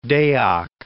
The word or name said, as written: Deak